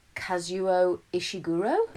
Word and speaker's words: Kazuo Ishiguro: Kazuo Ishiguro? – Yep.